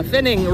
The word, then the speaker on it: Finning: Yeah, Finning rhymes with winning. F-I-N-N-I-N-G.